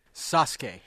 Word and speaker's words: Sasuke: Sasuke. – Sasuke. So the U is silent.